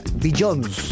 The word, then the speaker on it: Beyoncé: Por mucho tiempo yo estaba convencido de que la forma correcta de decir el nombre de esta artista era Beyoncé.